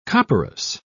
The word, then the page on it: copperas: British English